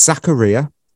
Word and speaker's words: Zakaria: I mean, I've just checked the pronunciation. It's Zachariah.